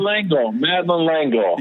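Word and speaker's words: Madeleine L'Engle: Madeline Langle.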